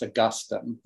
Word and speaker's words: Augustine: St. Augustine. Wow. What a great name. – Yeah, except as we know, it should be pronounced Augustine. – Yeah. – But the people here don't have the correct pronunciation.